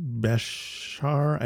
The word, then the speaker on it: Bejar: And then um, Dan Bejar, I don't I don't know how to pronounce his last name.